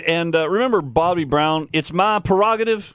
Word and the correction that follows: prerogative: It is prerogative. P-R-E. – Well, everybody pronounces that one wrong. – Prerogative. It's pre. P-R-E. Prerogative. – Nobody pronounces the first R. – I know. I'm just helping you out. – Prerogative, because pre means before.